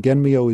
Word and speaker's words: Genmyo: It's it's written with Chinese characters, and Genmyo is the Japanese way of pronouncing it. The Chinese way of pronouncing it would be Xuan Miao, excuse my poor Chinese.